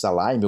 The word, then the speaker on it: Lime: Mas não podemos esquecer que ainda em janeiro, aí foi no começo do mês de janeiro, a americana de aluguel de patinetes Alaime, ou Lime, como você preferir, né, na pronúncia aqui é, localizada Brasil, vamos colocar dessa forma, anunciou o fim das suas operações também no nosso país.